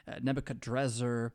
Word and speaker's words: Nebuchadnezzar: You know, Nebuchadnezzar's no joke in his own right. His name, apparently, is a mixture of names. You could pronounce it different ways, too. Nebuchadrakara and Nebuchadrezzar. But